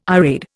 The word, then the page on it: irade: Indian